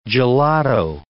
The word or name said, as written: gelato